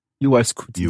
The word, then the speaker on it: UY Scuti: Is it U Y Scuti? – UY Scuti.